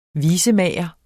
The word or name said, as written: visemager